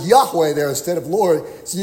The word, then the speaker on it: Yahweh: And that's the, if you pronounce it as it should be pronounced, I think most scholars agree it should be Yahweh.